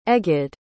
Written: egyed

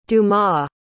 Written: Dumas

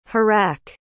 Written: harac

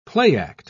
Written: playact

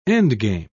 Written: endgame

